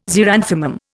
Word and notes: xeranthemum: Indian

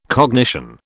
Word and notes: cognition: British English

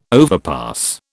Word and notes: overpass: British English

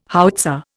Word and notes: howitzer: Indian

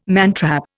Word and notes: mantrap: Indian

